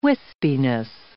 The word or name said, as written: wispiness